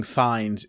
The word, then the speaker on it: Fiennes: I mean, it's pretty close, but his name is spelled like Fiennes. – How do you say it? – Fiennes being fines is, you know, whatever. But Ralph being Rafe, I just don't understand. – He's Rafe Fiennes. – Rafe Fiennes. Yeah.